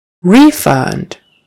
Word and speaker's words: refund: Refund. So accent on the first syllable, a refund.